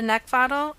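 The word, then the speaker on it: Neckvatal: Over on YouTube and LinkedIn, you can find me at Brenda Neckfaddle, and last name is spelled N-E-C-K, like the thing you want to choke.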